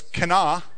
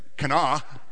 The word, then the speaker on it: Cana: So it's Cana. Oh, come on now, that's fun to say. Like, Cana. Kind of like cannoli, is what I can think of anyway.